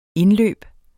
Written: indløb

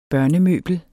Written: børnemøbel

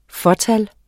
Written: fåtal

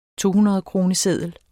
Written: tohundredkroneseddel

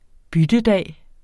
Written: byttedag

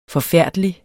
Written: forfærdelig

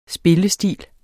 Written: spillestil

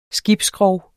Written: skibsskrog

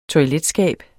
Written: toiletskab

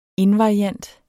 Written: invariant

